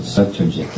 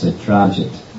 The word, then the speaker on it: Satrajit: The narrator pronounced it Satrajit, but it's Sitrajit. Sitrajit.